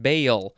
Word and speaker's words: Baal: Now we have, let's see, bale. I think it's how it's pronounced. Bale. B-A-A-L.